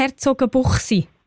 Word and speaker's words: Herzogenbuchsee: Der kommt von Herzogenbuchsee. – Merci vielmals, danke, danke. – Habe ich es eigentlich richtig gesagt? Herzogenbuchsee? – Ist genau, gut so? Ja, das ist so.